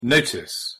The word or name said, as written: notice